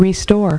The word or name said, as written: restore